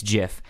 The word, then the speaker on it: GIF: The correct pronunciation as of the creator of a Jif says it's Jif.